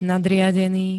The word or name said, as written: nadriadený